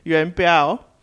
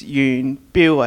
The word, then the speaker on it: Yuan Biao: So Mandarin is Yuan Biao. – Oh. – So that's why you get the B-I-A-O. So in Cantonese, it's Yuan Biu, I think.